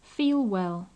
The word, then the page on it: feel well: English